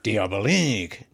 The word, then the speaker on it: Diabolique: that oh, wait, French I gotta say it right. Diabolique.